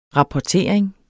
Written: rapportering

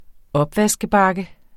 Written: opvaskebakke

